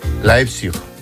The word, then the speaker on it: Leipzig: ¿Cómo se dice? – Leipzig. – Leipzig. Leipzig, ¿ves? – Leipzig, muy bien.